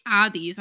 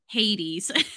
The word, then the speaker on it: Hades: And so, by the way, for those who don't know, when I say Addies, I'm saying Hades.